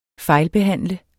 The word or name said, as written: fejlbehandle